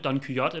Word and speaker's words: Don Quixote: Hvor er Don, altså, ham det, hvad, hvad der udtaler Don Kyoto? Jeg tror, det er Don Quixote eller Don Quixote. Don Quixote.